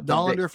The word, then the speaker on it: Dollander: Yeah, there's Chase Dollander. Is that how you pronounce it? – Dollander from Tennessee.